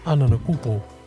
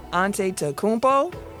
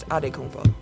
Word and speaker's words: Antetokounmpo: You can either say it as it's spelled, so Antetokounmpo. Or you can say it with the Nigerian pronunciation, which is Adekunpo.